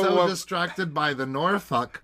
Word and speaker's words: Norfolk: I think it's pronounced Norfolk. – It's in England, so I think that's... Yeah! – Norfolk. Norfolk. Norfolk. – It's without the L.